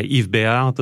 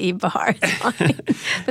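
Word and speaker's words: Yves Béhar: Anyways, she had this black and white case custom designed by Yves Béat, the Swiss industrial designer. – And it looked, you don't have to say it like you can say Yves Béat. But I like the way you said it.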